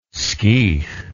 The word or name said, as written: skiech